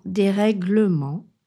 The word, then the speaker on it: dérèglement: On doit prononcer le E dans dérèglement et on ne peut pas dire dérèglement.